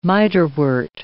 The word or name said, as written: mitrewort